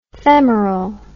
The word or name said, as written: femerell